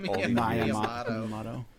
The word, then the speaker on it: Miyamoto: Miyamoto.